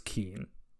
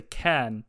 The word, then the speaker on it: Kean: So we all like fixed our pronunciation to say Ken, because someone told someone else Ken, and then we all said Ken for like 6 months, and then we heard him say it, and we're like, great, sweet. So we've been saying it wrong this whole time.